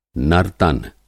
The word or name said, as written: नर्तन